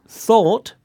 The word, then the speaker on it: thought: Now, the words thought, spelt T-H-O-U-G-H-T, and ought, spelt O-U-G-H-T, both have the O-U-G-H pattern in them, and it's pronounced all. Thought. Ought. – Thought. Ought. – Hmm. – And there are more O-U-G-H verbs with the same pronunciation, like fought, the past of fight, and bought, the past of buy.